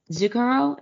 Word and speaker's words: Zucchero: Bono's gonna be on Zucchero. Is that how you pronounce his name? – Yes.